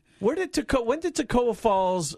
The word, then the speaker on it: Toccoa: No M in it. Tocoa Falls. T-O-C-C-O-A. Pronounce it for me. You've been in radio for a long time. T-O-C-C-O-A. – Tocoa Falls. – Tocoa Falls.